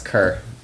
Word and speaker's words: Kerr: If I may, though, I think it's pronounced Kerr. – Yeah, probably. – K-E-R-R, right. – You said? – Is that German? – Oh, there's a street in Oakville spelled the same way, and it's Kerr.